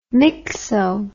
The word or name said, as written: myxo